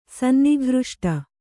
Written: ಸನ್ನಿಘೃಷ್ಟ

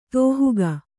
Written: ದಾಹಕ

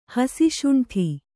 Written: ಹಸಿಶುಂಠಿ

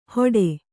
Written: ಹೊಡೆ

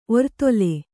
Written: ಒರ್ತೊಲೆ

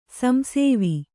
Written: ಸಂಸೇವಿ

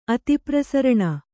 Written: ಅತಿಪ್ರಸರಣ

